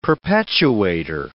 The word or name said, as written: perpetuator